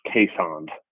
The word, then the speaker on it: Keeshond: There's a dog that's commonly mispronounced, the Keeshond. It's got two E's from Dutch, you know, like Holland. And it's actually pronounced Caissond.